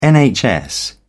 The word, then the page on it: NHS: British English